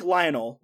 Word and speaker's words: Lionel: It's spelled like Lionel, like Lionel Richie, but it's pronounced Lionel. – Okay. – So L-I-O-N-E-L.